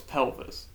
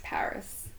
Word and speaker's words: PVRIS: Speaking of music, there is a band that I've just recently started listening to, thanks to Spotify, called Paris, and it's like P-V-R-I-S. – So it's pelvis? – No, it's Paris.